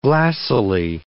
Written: glassily

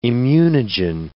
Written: immunogen

